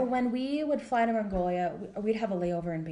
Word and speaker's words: Mongolia: Did you, you, guys travel a lot um, when you were missionaries in Mong? I can never say it right. Mongolia.